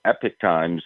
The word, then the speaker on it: Epoch Times: Well, both of them are actually correct. If you, American way is, you know, usually called Epic Times.